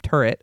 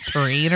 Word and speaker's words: tureen: It's not a soup turret. A turret's like a gunship. It's like a soup tureen or something.